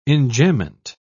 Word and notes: enjambment: British English

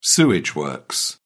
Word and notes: sewage works: British English

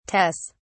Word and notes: tes: British English

